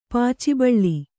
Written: ಪಚ್ಚೆಬಾಳೆ

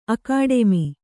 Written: ಅಕಾಡೆಮಿ

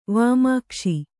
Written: ವಿಮೋಕ್ಷ

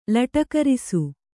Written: ಲಟಕರಿಸು

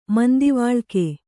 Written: ಮಂದಿವಾಳಿಕೆ

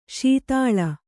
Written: ಸ್ಥಾಲಿ

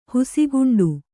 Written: ಹುಸಿಗುಂಡು

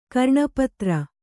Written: ಕರ್ಣಪತ್ರ

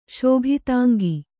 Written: ಶೋಭಿತಾಂಗಿ

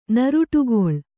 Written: ನರುಟುಗೊಳ್ಳು

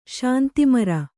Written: ಶಾಂತಿಮರ

